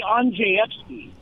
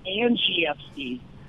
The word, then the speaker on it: Andrzejewski: Well, in Polish, they say Andrzejewski but we've tried to help out over the years. My family pronounces it Angieski, just to make it a little easier.